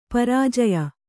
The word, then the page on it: ಪ್ರಾಜ್ಯ: pfrājya